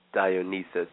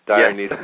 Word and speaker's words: Dionysus: And again, Dionysus, did I say that correctly this time? – Dionysus. Yeah, the Greeks say it like that. – I'm getting closer. Dionysus, yes.